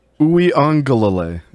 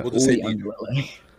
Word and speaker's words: Uiagalelei: he's not going to be as good as Uiagalelei, or however you pronounce his last name. – Uiagalelei, yeah. – Uiagalelei.